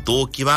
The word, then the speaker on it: 動機: 意思決定の原因は動機、入学年度が同じ仲間は同期。それではもう一度、意思決定の原因は動機、入学年度が同じ仲間は同期。上手に言えましたか？この動機も、同音異義語がたくさんあります。胸がドキドキする動機は、意思決定の原因の動機と同じ平板のアクセント。